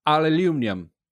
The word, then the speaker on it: aluminium: Um, my favorite British pronunciation of anything is the word aluminum, uh, oh, pronounced oh, oh, yeah. by someone in Europe. Aluminium.